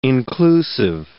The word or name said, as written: inclusive